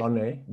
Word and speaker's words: Blonay: Is it Blonay? – Blonay. – How do you pronounce? Sorry? – Blonay. Blonay. – Blonay. Blonay. – It's French.